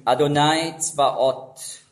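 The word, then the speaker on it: Adonai Zvaot (אדוני צבאות): Auf Hebräisch ausgesprochen, Adonai Zvaot, beziehungsweise so wie es im Judentum nicht ausgesprochen wird, aber wörtlich da steht, Yahweh Zvaot, eben der Ewige der Heerscharen.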